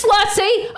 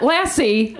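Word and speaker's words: lassi: That's right. It's lussy. I'm a white person that reads and retains. We're all pronouncing it wrong. It's not lassie like the dog. It's lussy like I just said.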